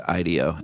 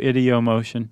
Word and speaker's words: ideomotion: Which I heard someone pronounce it ideo, and I didn't know if they were just being fancy or not. – It can go either way. – Okay. – Ideo, ideomotion.